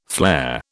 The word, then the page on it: flair: British English